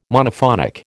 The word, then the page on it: Monophonic: American English